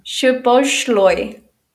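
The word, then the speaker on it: Szoboszlai: How do you pronounce it again? – Shaboshloy.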